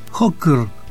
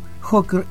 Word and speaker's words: Hokr: En nuestro primer bloque, según les anuncié al inicio, les traigo a los checoslovacos Hocker, se escribe así, H-O-K-R, simplemente, y su Starek Hocker, el volumen 2.